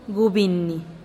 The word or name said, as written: gubinih